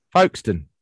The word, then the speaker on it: Folkestone: Folkestone.